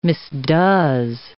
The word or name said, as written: misdoes